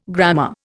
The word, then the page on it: grammar: Indian